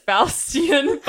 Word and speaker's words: Faustian: As she realizes the limitations of her Faustian bargain, is that how you even pronounce that word? I'm pretty sure that's Faustian, but keep going.